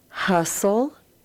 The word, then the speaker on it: hustle: And the words in the center of the page also end with the L-E, but the T is silent. So let's read these words. Hustle, bustle, rustle.